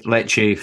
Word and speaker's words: Lecce: Is that how you say it, Lecce? – Yeah, I believe so. – Yeah, Lecce for circa £1.1 million from Aberdeen.